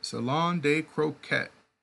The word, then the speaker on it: Salon de Croquette: Salon de Croquette.